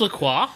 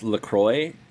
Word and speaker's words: LaCroix: Is it pronounced LaCroix? Really? – I think in America it's pronounced LaCroix, but in French, LaCroix. I mean, it just means cross.